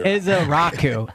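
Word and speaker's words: Ezeiruaku: The Eagles used one of their top 30 visits on Boston College edge rusher Donovan, I'm learning how to pronounce the name, Ezezuka. – You don't get credit until you say the name right. – It's a tough name to pronounce. – Donovan here. – Ezaraku. Ezaraku. – Ezaraku. – Is how you pronounce it. Phonetic.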